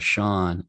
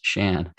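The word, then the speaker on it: Seán: When I did the Irish studies program in college, I learned that the proper Gaelic spelling of my name actually has a little slash. It's called a fada over the A. And that as actually the pronunciation of Sean. Without the accent, it's pronounced Shan, and it means old.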